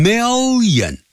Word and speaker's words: million: 就好像一百万叫 one million，第一个 l 给那个 mil，第二个 l 呢 yen， million 啊 million million，不要念成 million，不是这样念法啊。